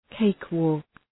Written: cakewalk

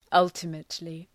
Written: ultimately

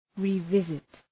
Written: revisit

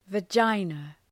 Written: vagina

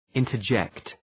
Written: interject

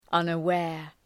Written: unaware

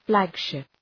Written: flagship

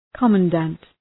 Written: commandant

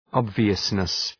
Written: obviousness

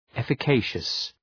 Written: efficacious

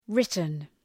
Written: written

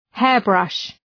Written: hairbrush

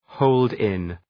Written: hold in

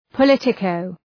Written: politico

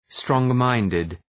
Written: strong-minded

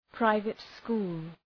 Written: private school

